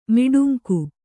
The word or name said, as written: ಮಿಡುಂಕು